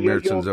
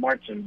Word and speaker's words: Märzen: I had a few Märzens over the weekend. – You'll find 1.5 million people in the U.S. calling it Marzen, but they're. The German pronunciation is Märzen.